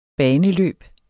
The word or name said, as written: baneløb